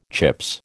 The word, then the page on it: Chips: American English